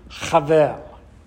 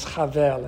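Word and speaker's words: Havel (הבל): In Hebrew, Havel. His name is Havel in Hebrew. In English, pronounced Abel.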